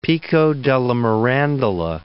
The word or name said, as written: Pico della Mirandola